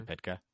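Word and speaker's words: Pytka: So it was directed by, Joe Pitka? Is that how you pronounce his name? – Yeah, I'd say Pitka. That's fair. – Yeah. Anybody know any different? No? All right. – Nope. – Go with Pitka.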